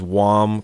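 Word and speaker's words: Jaume: It's I don't even know how to pronounce his first name. It's J-A-U-M-E. – Jones. The same way you pronounce those horses in The Terrible Game. – Oh, okay. – There's a J next to a V. – His name is, I guess it's Wam. – Wam.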